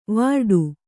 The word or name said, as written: ವಿರಾಟ